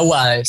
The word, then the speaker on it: UADE: De la UADE, sí, sí. Ah, ok, eh, lo dije bien.